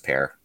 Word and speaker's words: Pierre: Funnily enough, Pierre is the capital of South Dakota, but I think they pronounce it Pear, but whatever.